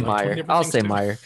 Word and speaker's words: Mayer: I never know if it's Meyer or Meyer. How do you say it? – I thought it was Meyer, but hey. I'll say Meyer. M-A-Y-E-R, so there you go.